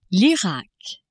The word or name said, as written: Irak